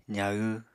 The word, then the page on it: zahw: apatani